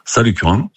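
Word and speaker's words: Salles-Curan: Vous êtes pompiste à, alors je ne sais pas si je vais bien le prononcer, on dit Salles-Curent ou Salles-Curent? – Salles-Curent, dans l'Aveyron.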